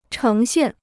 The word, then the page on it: 呈现: chéng xiàn